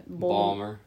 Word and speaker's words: Baltimore: How do you say Baltimore in the Baltimore accent? – Balmer.